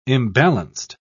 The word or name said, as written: imbalanced